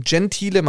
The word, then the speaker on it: Gentile: Also Fabio ist mein Vorname und Gentile mein Nachname. Genauso spricht man das aus.